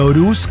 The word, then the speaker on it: Aarhus: Voy a ir a, Aarhus, que se pronuncia Aarhus, que es en Dinamarca.